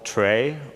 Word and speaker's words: trie: And it turned out that after quite a lot of experimentation, that the optimum data structure for that is a tree that's written T-R-I-E. Some people call it tray, or pronounce it tray.